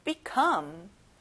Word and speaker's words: become: come에 강세를 넣어보는 거예요. become.